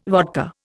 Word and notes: vodka: Indian

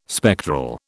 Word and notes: spectral: British English

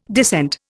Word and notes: descent: Indian

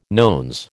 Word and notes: nones: American English

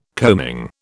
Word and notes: coaming: British English

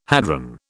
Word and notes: hadron: British English

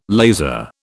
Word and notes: laser: British English

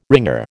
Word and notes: wringer: American English

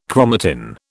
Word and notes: chromatin: British English

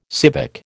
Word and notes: civic: American English

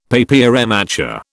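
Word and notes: papier mâché: British English

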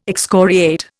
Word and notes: excoriate: Indian

